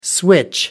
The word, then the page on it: switch over: British English